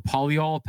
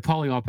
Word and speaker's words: polyol: Is it called the polyol pathway? Yeah. Okay, the polyol pathway.